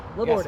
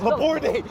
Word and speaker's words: Laborde: Yes. Laborde. The second syllables gets the most love. I have to figure that out. – My old woodworking teacher used to say, Laborde, eh? Like a Canadian, and that's closer than most ways.